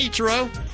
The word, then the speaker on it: Ichiro: Hi, Ichiro. – Hi, how are you? – Very well. Am I pronouncing your name right? – You got it right.